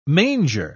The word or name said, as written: manger